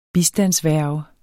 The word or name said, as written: bistandsværge